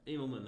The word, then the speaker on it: Immelman: Immelman. – Like Trevor. Immelman, the golfer.